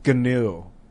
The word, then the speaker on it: GNU: Yeah, but so Richard Stallman said it's GNU. His software, new, it's GNU, like the animal, the new. And he invented it, so he's correct. – Yeah, but GNU is pronounced new, not GNU.